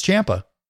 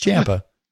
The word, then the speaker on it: Ciampa: And that's the right way to say his name, Tommaso Ciampa. Rhymes with Tampa. No, it's Ciampa. It's Ciampa.